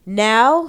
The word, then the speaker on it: Nanao: Um, now, how do you pronounce her name? N a n a o. Now, now. Yeah, now.